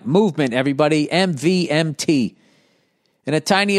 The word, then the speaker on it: MVMT: And clean, original designs, MVMT, pronounced movement, grew into one of the fastest growing watch brands, shipping to over 160 countries across the globe.